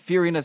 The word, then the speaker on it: Fiorina: I mean, Carly Fiorina, is that how you say her name? – Fiorina.